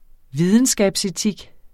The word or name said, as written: videnskabsetik